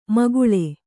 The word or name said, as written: ಮೇಖಳೆ